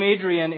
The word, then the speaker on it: Adrian: My name, Adrian, in Pakistan, people had a hard time pronouncing it. People would call me Aryan, Orion, and a bunch of other things.